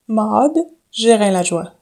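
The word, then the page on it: Maude Gérin-Lajoie: English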